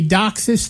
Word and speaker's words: DOCSIS: DOCSIS, yeah.